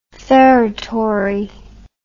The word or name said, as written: feretory